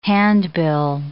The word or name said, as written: handbill